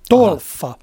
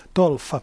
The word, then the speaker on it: Tolfa: kuin Tulfa. Tulfa. Tolfa, anteeksi. Niin se vissiin lausutaan, t o l f Tolfa joka sijaitsee siis noin suunnilleen siinä Civitavecchian kohdalla.